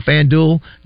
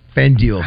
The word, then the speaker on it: FanDuel: Did you hear trying to focus on a FanDuel? Duel, the word duel? – I still don't think I'm saying it incorrectly.